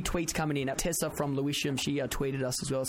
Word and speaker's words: Lewisham: It's Lewisham.